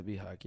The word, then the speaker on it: BHAG: A BHAG, yeah. But the H is silent.